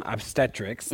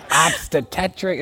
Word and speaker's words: obstetrics: Now, in prepping to talk to you tonight, I learned about the connection between modern obstetrics. I said it right? – That's right. – I was up there. I was practicing, y'all. I'm like, obstetetric.